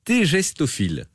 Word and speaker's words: tégestophile: Et puis, il y a une bourse tégestophile. Je prononce bien? – Ouais, c'est bien ça.